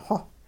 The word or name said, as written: xowh